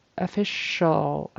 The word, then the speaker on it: official: Official, that C should be an SH kind of sound. Official.